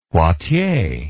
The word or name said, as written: Poitiers